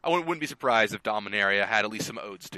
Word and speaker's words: Dominaria: Dominaria.